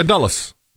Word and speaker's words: Dulles: Dulles. I feel like I'm saying that wrong. It's in Washington, D.C., I believe. – Yeah, Dulles.